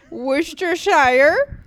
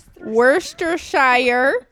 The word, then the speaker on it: Worcestershire: One, you're just completely forgetting the W at the beginning of that word. – Worcestershire. Worcestershire.